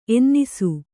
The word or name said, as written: ಎನ್ನಿಸು